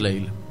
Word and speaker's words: Lale: Se escribe Lale Anderson, no sé si es Lale. Sí, es Lale.